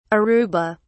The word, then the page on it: aruba: British English